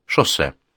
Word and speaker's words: Жозе: Они остановились в махонькой гостинице с махоньким бассейном, с махоньким рестораном, и заправлял всем этим махоньким хозяйством махонький человек по имени что-то вроде Шоссе. При этом писалось оно «Жозе», но, как понял Уве, у них там в Испании с произношением не очень заморачиваются.